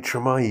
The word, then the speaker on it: Treme: I certainly haven't spoken about him since learning to pronounce his name correctly, and that's Nate Treem. Hold up. No, it isn't. It's Nate Treme, you plonker.